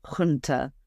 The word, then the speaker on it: junta: I think, is it junta? That's how you say it? – Well, yes, you can say it. Or the Greeks would say junta. Then they have this in their alphabet, this sort of he sound that comes from the throat, and actually junta, it sounds more sinister than junta.